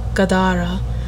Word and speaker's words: Gadara: He even claims it spoke to him, uttering the word Gadara, with the middle syllable emphasized.